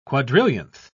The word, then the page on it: quadrillionth: British English